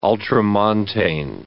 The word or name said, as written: ultramontane